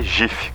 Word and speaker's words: GIF: Tá, o Henrique 1537 mandou: a pronúncia é GIF, como em girafa.